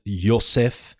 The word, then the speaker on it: Yosef: Remember the J in Hebrew, you pronounce as a Y. So if you speak English mostly, it's not Jacob, but it's Yaakov. It's not Joseph, but it's Yosef. And then Jesus, of course, would be Yeshua.